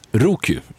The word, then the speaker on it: Roku: Roku.